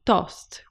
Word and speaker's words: toast: So in Italian, the word toast or tost with the Italian pronunciation is actually a toasted sandwich.